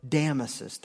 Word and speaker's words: Damasus: And when Theodosius called this meeting of the bishops together to reaffirm what was agreed on at Nicaea, he did not invite Damasus. That's how his name is pronounced. I may have said that wrong, but it's Damasus. He only invited the bishops in the Eastern Empire, not the Western Empire.